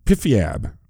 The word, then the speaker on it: PFIAB: I know they pronounce that acronym, PIFIAB.